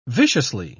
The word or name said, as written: viciously